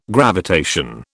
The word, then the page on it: gravitation: British English